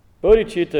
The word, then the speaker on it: bodhicitta: And again, you'll have notes and stuff, and there's books, but it's B-O-D-H-I-C-I-T-T-A, bodhicitta. C-I is pronounced ch.